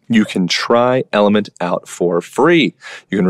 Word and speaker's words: LMNT: If supplementing your electrolytes seems right for you, I highly suggest Element. It's spelled L M N T.